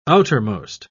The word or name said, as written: outermost